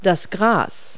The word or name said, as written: grass